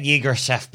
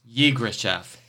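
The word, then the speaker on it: Yirgacheffe: Yes, it took me a little bit to get Yegrashef, but I've gotten it now. – Yeah, it doesn't look like it's spelled that way, but Yegrashef, and it's my favorite.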